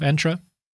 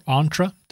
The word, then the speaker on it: Entra: The other thing that's happened in Intune, and sort of kind of Entra, or Entra, depending on which pronunciation you want to use, is that we have new Windows Lapse settings.